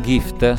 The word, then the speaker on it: GIFT: dove lei però dà un po' più spazio allo yogurt e ad altri latticini rispetto a una dieta paleolitica, e alla dieta GIFT, scritto G-I-F-T, anche se è un acronimo italiano, si pronuncia come in inglese dono, del dottor Luca Speciani.